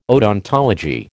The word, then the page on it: odontology: American English